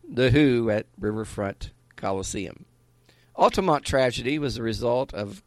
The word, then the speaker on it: Altamont: Altamont.